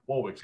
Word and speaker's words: Warwick: Warwick's getting it. I think it's called Warwick, isn't it? That's how we pronounce it.